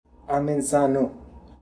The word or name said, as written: Amenzano